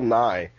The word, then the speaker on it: Nighy: It's actually just Bill Nye. – Bill Nye? – The science guy? – Yeah, well, that's why it confuses people when it's just pronounced and not written. But it's pronounced Bill Nye.